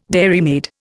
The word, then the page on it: dairymaid: Indian